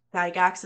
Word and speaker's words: Gygax: Gygax. – Gygax is how you say it? Yeah. Mm-hmm.